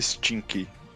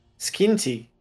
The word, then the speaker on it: Skinty: Que não é stinky. É. Skinty. Skinty.